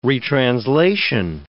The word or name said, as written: retranslation